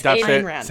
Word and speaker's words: Ayn Rand: It's Ayn Rand. – Say it again. – Ayn Rand. – Oh, no. Say it in a sentence. – No, oh, say it in the sentence. – Ayn Rand.